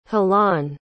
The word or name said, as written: jalon